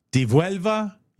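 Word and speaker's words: Devuelvo: It's not a double L. – Right, it's D-E-V-U-E-L-V-O. – Divielva? – Yep. Ducarta. – Ducarta. Divielva.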